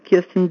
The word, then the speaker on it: Kirsten: Hello, my name is Kirsten DeBeer. First name spelled K-I-R-S-T-E-N and pronounced Kirsten, not Kirsten or Kristen. It is a Danish name originating from Christina. DeBeer is probably a misspelling of the Dutch last name DeBeer, spelled D-E-B-E-E-R.